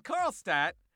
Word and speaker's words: Karlstadt: In Karlstadt, did I pronounce that one right? – Yes. – In Karlstadt, we're looking for a logistics operations associate.